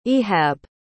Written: Ihab

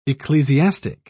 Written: ecclesiastic

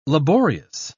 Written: laborious

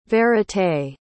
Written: vérité

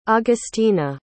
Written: augustina

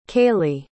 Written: cailey

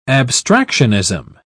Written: abstractionism

